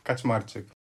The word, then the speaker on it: Kaczmarczyk: that's a hard one. That's a tongue twister, especially for the foreigners. For us, it's super easy to pronounce, but... Okay, so my full name is Jakub Kaczmarczyk.